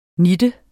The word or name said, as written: nitten